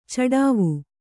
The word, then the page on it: ಚಡಾವು: caḍāvu